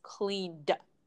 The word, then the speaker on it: cleaned: So you can imagine that cleaned, the pronunciation cleaned, imagine that it's spelled C-L-E-A-N-D. – Mm. So again, the E in there is silent. There's no cleaned. You don't need to add a vowel there. So it's just cleaned. – Right, right. You're not adding another syllable. You're just adding the D sound.